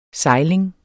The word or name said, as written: sejling